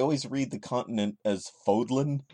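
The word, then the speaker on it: Fódlan: But Fodlin, yeah, Fodlin. That's kind of fun to say. – It rhymes with yodlin.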